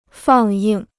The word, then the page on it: 放映: fàng yìng